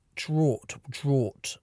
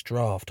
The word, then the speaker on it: draught: But then it would be like, draught. And it wasn't until I became an adult and heard people saying it's draft, I was like, shit, I've been saying it wrong all these years.